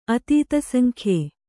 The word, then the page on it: ಅತೀತಸಂಖ್ಯೆ: atītasaŋkhye